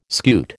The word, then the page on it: scute: American English